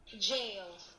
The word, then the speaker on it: gaol: And we have a computer algorithm here that's pronouncing the word G-A-O-L, and it sounds like... – jail. – Thank you very much. So yes, Andrew was correct on that one, apologies.